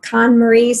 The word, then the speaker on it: KonMari: I'm wondering what it means to be KonMari certified.